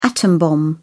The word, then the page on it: atom-bomb: British English